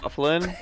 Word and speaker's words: Byfuglien: Is it Byfuglien? – Bufflin? – I say Byfuglien to remember how to spell it.